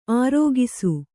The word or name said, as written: ಆರೊಗಿಸು